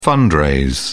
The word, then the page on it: fundraise: British English